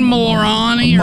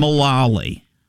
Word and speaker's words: Mullally: How do you say her name? – Megan Mulrani or Mulani? – Mulally. Mulally. – Okay, Megan Mulally.